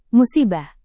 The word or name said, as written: musibah